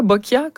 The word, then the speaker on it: boccia: Boccia, boccia? Est-ce que je le prononce comme il faut? – Oui, oui, c'est bien boccia.